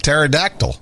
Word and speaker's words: pterodactyl: The P is silent in pterodactyl.